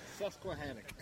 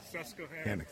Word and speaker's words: Susquehannock: What's the exact pronunciation? – Susquehannock. – Susquehannock. Yeah, Susquehannock it is.